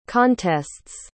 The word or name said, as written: contests